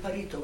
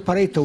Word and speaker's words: Pareto: Pareto, why do you call it like that? Oh. Pareto. – I'm pronouncing it wrong. – No, well, the only one I know is Vilfredo Pareto was an Italian sociologist, so I just wonder whether it comes from him.